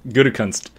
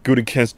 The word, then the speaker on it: Gutekunst: Not even very close. – All right, how do you say it? – Gutekunst. – Oh, totally different. Gutekunst. – There's no R.